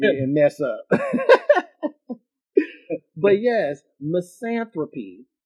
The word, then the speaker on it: misanthropy: Misanthropy. MISANTHROPY. Misanthropy. And I guess that would make me a misanthropist, which is the dislike of humankind. – Misanthropy.